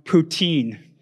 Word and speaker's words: poutine: I'll just say, it's pronounced poutine. Mm. Poutine.